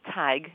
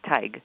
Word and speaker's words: Tadhg: Tig. He's got kind of an odd name. T-A-D-H-G. But you say it like tiger without the R on the end. Tig. – Now, wait a minute. T-A-D-G-E? H-E-G? I'm sorry. – T-A-D-H-G.